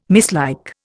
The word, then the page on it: mislike: Indian